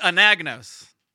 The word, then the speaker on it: Anagnos: So it's Anagnos.